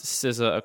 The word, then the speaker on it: SZA: It does seem to be pronounced SZA according to the Wikipedia pronunciation guide. – I always assumed it was like RZA, like the RZA and the JZA. Like, why wouldn't it be like that? Because it's spelled the same way as the RZA? – That's kind of what I assumed too. And it turns out, I guess that is what it is.